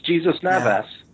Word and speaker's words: Navas: Yeah, it's Jesus Navas.